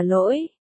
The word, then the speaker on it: lỗi: Mênh mông, mênh mang, ba chữ tảng đá hợp lại đọc là lỗi,